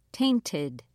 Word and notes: tainted: British English

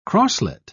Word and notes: crosslet: British English

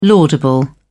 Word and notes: laudable: British English

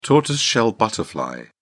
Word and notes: tortoiseshell butterfly: British English

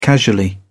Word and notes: casually: British English

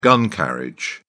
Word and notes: gun carriage: British English

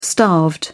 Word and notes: starved: British English

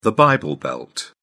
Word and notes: bible belt: British English

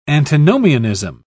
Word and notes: antinomianism: British English

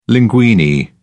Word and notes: linguine: British English

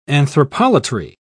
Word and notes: anthropolatry: British English

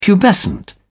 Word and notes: pubescent: British English

